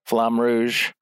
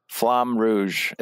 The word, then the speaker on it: Flamme Rouge: I went back out to the Grand Foyer and played Flamme Rouge with the designer Angser. Grand Rude. – Hold on, you got to spell that one. Flam what? Flambe? What was Flam? Flamme Rouge.